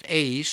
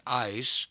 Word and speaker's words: eis: Now this word for, in the phrase for the remission of sins, it's from the Greek word eis, pronounced ace, sometimes pronounced ice.